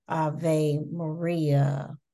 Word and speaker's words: Ave Maria: You could do a movie, you could do an opera, Ave Maria, right? Is that the song? – Yes, you said it right.